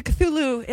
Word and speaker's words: Cthulhu: Well, I think I got, well, I got as close to Cthulhu.